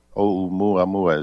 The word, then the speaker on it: Oumuamua: And it's called, they named it Oumuamua. Oumuamua is how it's pronounced.